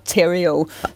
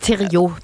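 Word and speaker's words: Theriault: Austin, og så er også hans efternavn meget fransk, og jeg skulle lige til at kalde på dig, for at finde ud af, hvad man udtalte. Men heldigvis, så siger han selv bare Terrio.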